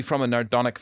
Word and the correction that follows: Narodnik: family. – Can we call him Stefan? – Narodnik, I think is the pronunciation. Narodnik, yeah, sorry.